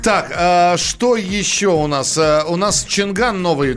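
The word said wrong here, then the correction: Changan: Э, Чанган. – Чанган, да, кроссовер. Mm-hmm. – Чан Ан, по-моему, правильно по-китайски О, боги, про- это...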